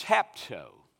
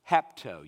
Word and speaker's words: hapto: It's hapto. Hapto. You'd spell it H-A-P-T-O.